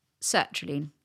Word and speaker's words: sertraline: I'm looking to come off sertraline. Max tells me that's how to pronounce it.